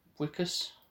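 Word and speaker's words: Wikus: I believe it's pronounced Wickus.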